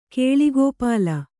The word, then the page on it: ಕೇಳಿಗೋಪಾಲ: Kannada